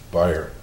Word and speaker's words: Bayer: B-A-Y-E-R, it's spelled like Bayer, but it's pronounced Beyer.